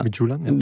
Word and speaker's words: Midtjylland: FC Midjulan. Ja. – Midjulan. – Ja. Midtjylland.